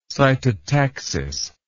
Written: cytotactic

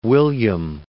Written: William

